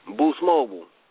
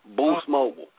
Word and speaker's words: Boost Mobile: Deuce Mobile? – Boost Mobile. – Boost? – Yes. – Oh, shit. – It's spelled B-O-O-S Mobile. Boost Mobile.